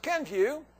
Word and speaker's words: Kenvue: It's pronounced Kenvue.